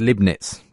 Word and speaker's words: Leibniz: Apparently, he's not even familiar with Gottfried Leibniz, pronounced Leibniz, according to you.